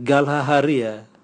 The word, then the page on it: galhaharia: Raga